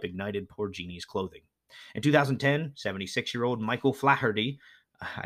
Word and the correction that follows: Faherty: never know how to pronounce those names, no, not Flaherty, Faherty, F-A-H-E-R-T-Y, Faherty, of Galway, Ireland, was found dead in his living room floor.